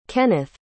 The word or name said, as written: kennith